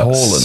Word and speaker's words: Haaland: No, it's just pronounced Haaland.